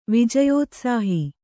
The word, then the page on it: ವಿಜಯೋತ್ಸಾಹಿ: Kannada